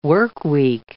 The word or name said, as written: workweek